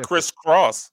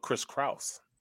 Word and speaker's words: Kraus: Yeah, it's spelled not Chris Cross, but Chris Kraus.